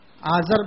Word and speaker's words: آزر: عربی زبان کے اندر اس کا تلفظ آزر سے ہوا ہے.